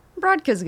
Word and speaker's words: Brodka: Brodka's good. – Okay. – Well, like in Polish, it's Brodka.